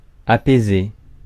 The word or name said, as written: apaiser